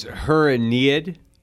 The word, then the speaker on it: Aeneid: Could you tell us how you pronounce the title of your short story? – Sure, it's Her Aeneid.